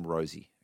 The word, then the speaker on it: Rozee: let's just call him Rosie. Okay? – Rosie is Rosie in the midfield. – Yeah, that's how you say it.